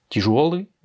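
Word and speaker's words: Tijuori (тяжёлый): Tijuori, eh bien, a son accent tonique sur le yo.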